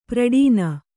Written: ಪ್ರಡೀನ

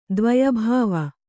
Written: ದ್ವಯಭಾವ

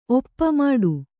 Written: ಓಪು

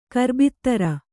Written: ಗಿರಬ್ದಾರಿ

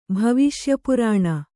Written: ಭವಿಷ್ಯಪುರಾಣ

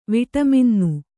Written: ವಿಟಮಿನ್ನು

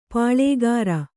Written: ಪಾಳ್ಳೆಗಾರ

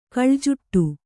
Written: ಕಳೆಜೂಟ

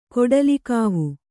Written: ಕೊಡಲಿಗಾವು

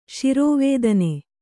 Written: ಶಿರೋವೇದನೆ

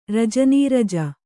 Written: ರಜನೀರಜ

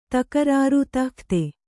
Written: ದಗದ

